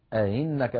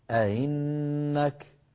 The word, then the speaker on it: أئنك: أئنك، لاحظوا بأن الهمزة المكسورة جعل علماؤنا علامتها دائرة مطموسة الوسط على السطر تلفظ هكذا أئنك.